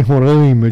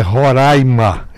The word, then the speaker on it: Roraima: Um dia desse eu disse numa entrevista que eu fiz com a Bandeirantes, que a apresentadora, a entrevistadora, me perguntou, falando em Roraima, eu digo, olha, não está correto. Você está pronunciando mal. O nome do meu estado é Roraima.